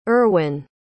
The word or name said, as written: erwyn